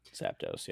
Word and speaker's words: Zapdos: Zapdos, yeah.